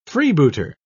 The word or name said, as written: freebooter